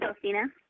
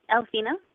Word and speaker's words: Alphina: Yes, hi, this is Elfina. – I'm sorry, what was your name? – Elfina. – Elfina, could you spell that for me, please? – A-L-P-H-I-N-A. – I-N-A.